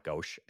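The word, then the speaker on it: Goetsch: He was, for people out there, it's spelled G-O-E-T-S-C-H. I think it's pronounced Ghosh. It's hard to say.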